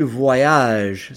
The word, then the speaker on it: voyagent: Parce qu'ils voyagent. Same thing. ILS, VOYAGENT. Totally skip the ENT and end it on the soft G.